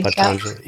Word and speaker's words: Patanjali: Patanjali.